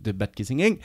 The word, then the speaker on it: Bad Kissingen: Parce que quand même, des, études comme celle de Bat, je te laisse le prononcer. – Bad. – Kissingen. De Bad Kissingen.